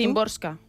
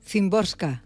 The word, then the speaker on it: Szymborska: lo ha apuntado cómo se pronuncia, para no A ver, ¿cómo lo pronuncias tú? – Szymborska. – Szymborska. – Entiendo yo que sí, por lo como yo he escuchado a la gente pronunciarlo.